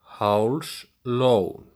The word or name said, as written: Hálslón